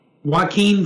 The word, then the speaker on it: Joaquin: Joaquin Phoenix creates is just unbelievably amazing in how deep it is.